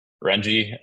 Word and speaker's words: Renji: Renji, that's how you say it. It's like Benji with an R.